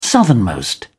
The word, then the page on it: southernmost: British English